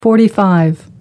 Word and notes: 45: American English

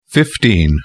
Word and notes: 15: British English

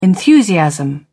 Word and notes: enthusiasm: British English

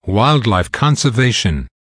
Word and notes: wildlife conservation: American English

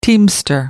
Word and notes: teamster: American English

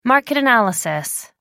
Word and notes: market analysis: American English

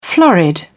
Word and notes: florid: British English

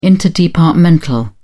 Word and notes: interdepartmental: British English

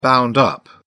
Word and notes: bound up: British English

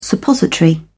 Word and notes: suppository: British English

